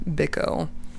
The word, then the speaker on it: Biko: I believe his last name is pronounced Biko.